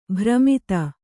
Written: ಭ್ರಮಿತ

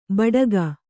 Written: ಬೆಡಗು

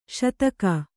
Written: ಶೇತಕಿ

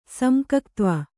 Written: ಸಮ್ಕಕ್ತ್ವ

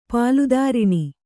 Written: ಪಾಲುದಾರಿಣಿ

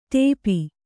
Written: ಟೋಪು

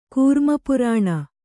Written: ಕೌರ್ಮಪುರಾಣ

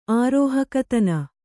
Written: ಆರೋಹಕತನ

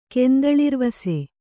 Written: ಕೆಂದಳಿರ್ವಾಸು

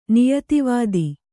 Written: ನಿಯತವಿಧಿ

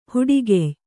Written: ಹೆಡಗಯ್

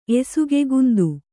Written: ಎಸುಗೆಗುಂದು

